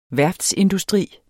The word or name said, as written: værftsindustri